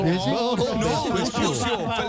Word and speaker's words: Vespucio: Con ese American Prepucio. – No, Vespucio, Felipe.